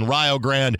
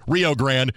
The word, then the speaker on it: Rio Grande: Again, briefly out west, in the Mountain Division, three teams will duke it out for the final spot between Rio Grande, again, the Ohio pronunciation, sorry, Rio Grande, New Mexico United, and Austin Bold.